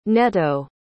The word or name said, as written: netto